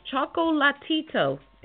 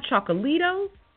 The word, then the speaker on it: Chocolatito: And once again, it's Chocolatito. It's not Chocolito, and it's not Chocolatito. Chocolatito is Michael Jackson's brother. I don't even know what Chocolito is, but it's Chocolatito. All the syllables.